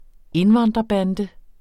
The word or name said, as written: indvandrerbande